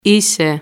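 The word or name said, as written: είσαι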